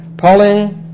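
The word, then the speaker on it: Palingaisia (παλιγγενεσία): It is a time of palingaisia. Like this. These two gammas, when they come together, are pronounced ing in Greek. P-A-L-I-N-G. We would have to pronounce this in English. Palingaisia. Palingaisia. P-A-L-I-N-G-E-N-E-S-I-A, if you're taking notes, okay?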